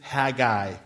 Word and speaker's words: Haggai: Now, some people pronounce Haggai Haggai. Now, there's nothing wrong with that other than that's the wrong way to pronounce it. If you do look at how the Hebrew is broken down, the most common way that you would pronounce these letters is Haggai. So I shorten it that way to Haggai.